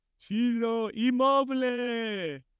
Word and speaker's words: Ciro Immobile: Ciro Immobile.